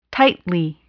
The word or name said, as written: tightly